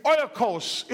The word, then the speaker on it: oikos: That word is oikos in the Greek. Oikos is how they say it.